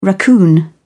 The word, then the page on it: raccoon: British English